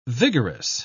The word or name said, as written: vigorous